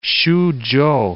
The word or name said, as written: Suchow